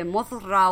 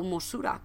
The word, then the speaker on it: Mothra: Mothra o Mosura, como se pronunciaría japonesamente, más o menos, que es la polilla esta gigante, y que es precisamente esta evolución.